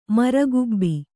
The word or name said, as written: ಮಾರಿಕಬ್ಬೆ